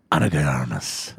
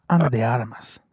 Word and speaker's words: Ana de Armas: With Ana de Armas. – Oh, okay. – And Chris Evans. – You're not doing it right, by the way. – How do you say it? – Ana de Armas.